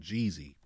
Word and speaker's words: Jheeze: And this song is called Jeezy, but it's spelled J-H-E-E-Z-E.